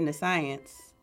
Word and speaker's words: nescience: N-E-S-C-I-E-N-C-E. And the problem is how this E comes off. Because it's basically, I want to say nescience, because that's science back there. – But? – I'm going to be fancy and probably wrong. But I hope you're wrong. – Nescience. Nescience. – That is one of the correct ways to pronounce it. There are three correct ways to pronounce it, and she got one of them.